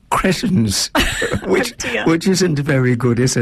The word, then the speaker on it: Cretans: It is Cretans. – Cretans.